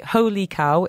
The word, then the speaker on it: Hoe Lee Kow: For anyone who's not familiar, first of all, we should say Ho Lee Kao is spelt a little bit different to Holy Cow. It is H-O-E. L-E-E-K-O-W.